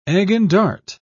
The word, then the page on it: egg-and-dart: British English